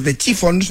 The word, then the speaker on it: The Chiffons: Años antes había triunfado otra creación, He's So Fine, compuesta por Ronald Mack e interpretada por The Chiffons, no sé si lo he dicho bien. – Lo has dicho estupendamente.